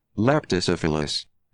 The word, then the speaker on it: Leptocephalus: It is called Leptocephalus.